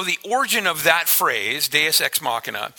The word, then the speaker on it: Deus Ex Machina: And it's pronounced Deus Ex Machina. And Deus Ex Machina is a Latin phrase, and it was derived from, it literally means, if you want to go to the next slide there, yeah, oh, thanks, Tyson.